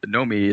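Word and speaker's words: Noomi Rapace: Noémie is pronounced No-me, and then like fucking nothing on Rapace.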